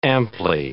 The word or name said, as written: amply